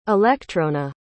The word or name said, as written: alectrona